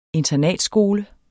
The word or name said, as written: internatskole